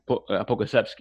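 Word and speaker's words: Pokuševski: I think that's how you say his name. Poku? – Pogusevski, yeah.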